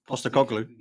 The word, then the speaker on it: Postecoglou: Postecoglou. – Yeah, that's it. Well done. Yeah. He's transformed. – Say it fast. – Yeah. – Say it fast and it's easier. – Postecoglou.